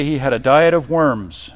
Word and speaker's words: Worms: And that council was called a diet, and it was held in Worms, is how you say it.